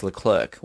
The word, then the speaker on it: Leclerc: So he actually says Charles Leclerc when everyone is like, Charles Leclerc.